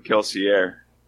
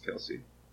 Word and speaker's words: Kelsier: Anyway, I've been calling him Kelsier. – That's what I said. – I thought you said Kelsey. But I guess if it's French, it could be pronounced Kelsey.